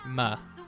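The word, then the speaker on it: MØ: So Lean On, Major Lazer, DJ Snake, and Muh, which as far as I understand, her name is pronounced Muh.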